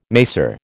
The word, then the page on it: macer: American English